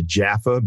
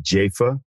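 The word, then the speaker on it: Jafa: I used to call him Arthur Jaffa, but apparently he now pronounces it Jaffa.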